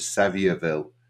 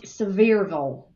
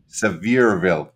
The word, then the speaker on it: Sevierville: And then finally we moved up to Sevierville, where, of course, Dolly comes from. I'm sorry, it's pronounced Sevierville. – Sevierville.